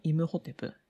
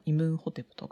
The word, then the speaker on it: イムホテプ: でまあ、そんな、えエジプトの医師なんですけれども最も有名な人物がいてそれがイムホテプ、まあ、イムンホテプとかいろいろな発音がありますけれどもイムホテプという人が非常に有名です。